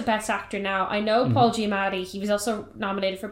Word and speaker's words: Paul Giamatti: Yeah, Paul Giamatti.